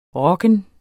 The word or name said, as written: ROKKEN